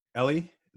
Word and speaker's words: Eli: Well, quickly, it's Ellie. But that's okay. – Oh, Ellie, sorry. – No problem. Happens all the time. It's spelled Eli.